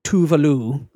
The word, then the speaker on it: Tove Lo: I'm like a little tired of Tove Lo. Tuvalu is how you say Tuvalu.